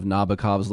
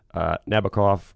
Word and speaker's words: Nabokov: This is a retelling of Nabokov's Lolita, of course. – And by the way, can we point out that Sting, the great lyricist, rhymed Nabokov or with cough?